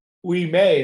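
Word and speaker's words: Ouimet: Didn't he competed against Francis Wee May. Is that how you say his name? – Yeah, it is.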